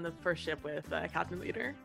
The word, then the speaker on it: Leeter: Leader? – Captain Leader. – I'm going to spell that L-E-E-T-E-R.